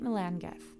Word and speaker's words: Melangell: It's spelled M-E-L-A-N-G-E-L-L. Saint Melangel is what it looks like, but it's Saint Melangeth.